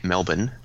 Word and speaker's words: Melbourne: Right, well, I don't know, people around here would say it Melbourne.